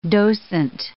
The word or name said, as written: docent